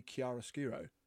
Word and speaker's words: chiaroscuro: Kind of lots of chiaroscuro lighting. Although I found out the other day, we've been saying it wrong the whole time. It's supposed to be chiaroscuro. We always said it wrong.